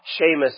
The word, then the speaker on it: Shema: It's called the Shema, or Shema, some people pronounce it.